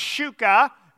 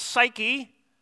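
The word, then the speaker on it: psyche (ψυχή): It's pronounced psyche. We sometimes pronounce it psyche.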